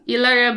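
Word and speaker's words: Hilaria: Ilaria Baldwin, if you will, is how she pronounces it.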